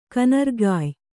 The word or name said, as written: ಕನರುಗಾಯ್